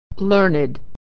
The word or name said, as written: learned